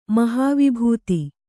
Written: ಮಹಾವಿಭೂತಿ

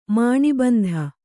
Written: ಮಾಣಿಬಂಧ

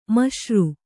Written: ಮಿಶ್ರ

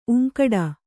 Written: ಊಂಕಿಡು

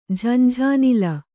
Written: ಝಂಝಾನಿಲ

